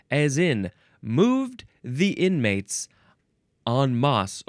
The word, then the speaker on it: en masse: I feel like en masse, that's the proper way to say it. E-N is the first word. The second word is M-A-S-S-E.